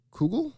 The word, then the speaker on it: kugel: I never pronounced this right. It's sweet noodle kugel? – Kugel, yeah.